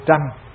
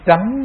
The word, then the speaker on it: tránh: Thông thường người Việt chúng ta không có rành âm Hán thì chúng ta nghĩ là khẩu hòa vô tranh. Nhưng mà thưa các vị, chữ này đọc là chữ tránh chứ không phải là tranh. Tránh như là tranh cãi, âm Hán Việt của nó là tránh.